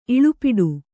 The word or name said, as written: ಇಳಿಪಾಟು